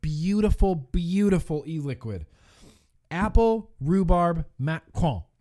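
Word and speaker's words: macaron: Okay, so I say macaroon, but it's not macaroon, right, Joshua? It's Macron. French pronunciation.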